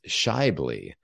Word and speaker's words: Schaeuble: So the correct pronunciation of my last name is Schaeuble. And you would never, I tell people to not look at the letters. Don't look at the letters. It will just confuse you.